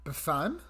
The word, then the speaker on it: Befana: But then one caught my eye, and that is La Befana. – La Befana? – La, space, Befana. So B-E-F-A-N-A. – Befana? – Befana. – Oh, Befana. La Befana? – La Befana, yeah.